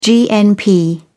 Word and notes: gnp: British English

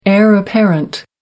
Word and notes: heir apparent: American English

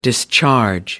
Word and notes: discharge: American English

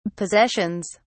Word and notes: possessions: British English

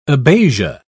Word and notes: abasia: British English

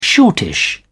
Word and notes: shortish: British English

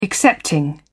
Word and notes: excepting: British English

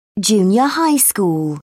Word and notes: junior high school: British English

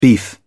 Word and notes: beef: British English

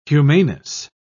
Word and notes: humaneness: British English

